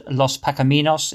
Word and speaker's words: Los Pacaminos: We're talking with Paul Young about his career and his Tex-Mex band Los Pacaminos. Is that how you pronounce it, Paul? – Yes, it is, yeah.